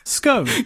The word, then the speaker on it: scone: And I got words like cone, blown, and shown, which would indicate it is indeed pronounced, Jordan, as you and all the other people you paid to sway the results of our poll would say, scone.